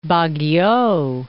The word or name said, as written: Baguio